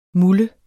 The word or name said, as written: muller